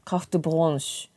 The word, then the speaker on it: carte blanche: But again, no need to make it sound French. Carte blanche.